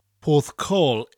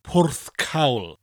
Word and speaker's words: Porthcawl: Now, the proper Welsh pronunciation for Porthcawl is Porthcawl. Porthcawl.